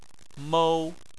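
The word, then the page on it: mou1: tone 1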